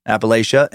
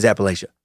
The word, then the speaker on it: Appalachia: on how to pronounce Appalachia, and 76% said that Appalachia is the way you say it.